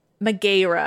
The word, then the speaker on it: Megaera: These three women, their names were Alecto, Megara, Megaera, I should say. Megaera probably is the pronunciation, and Tisiphone.